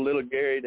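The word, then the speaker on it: Lil Gary: I'd be glad when it does, but I'm sure if you just Google Little Gary, that all kind of mess will pop up. But I spell it L-I-L, Gary, because my great-grandmother that raised me, that's how she spelled my name.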